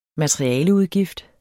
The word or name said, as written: materialeudgift